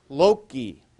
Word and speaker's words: loci: And so obviously the plural of locus is loci, or unless you're a church Latin soft C guy, loci. But I would go for loci because I was the hard Latin, okay, classical Latin. So loci. And this is also why, what is Melanchthon's great theological textbook called?